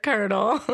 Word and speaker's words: colonel: Sign our petition to change the spelling of the word colonel. – Make it phonetic, please. How would you spell it? – K-E-R-N-E-L. – So like a kernel of corn.